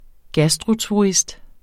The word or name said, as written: gastroturist